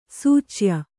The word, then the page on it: ಸಿಚಯ: sicaya; Kannada